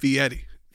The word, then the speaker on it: Fieri: Not Fieri. He gets real mad. – What is it? How do you say it? – Fee Eddie. He always says, it's like you're feeing Eddie. Fee Eddie.